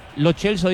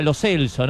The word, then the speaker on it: Lo Celso: Lo Chelso dijo que era Lo Celso, ¿no? – Sí, pero es Mecha, bueno, y es Rudiger.